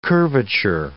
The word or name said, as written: curvature